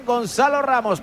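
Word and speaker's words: Gonçalo Ramos: Lo digo bien, Gonzalo Ramos, para controlar y despejar.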